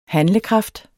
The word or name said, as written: handlekraft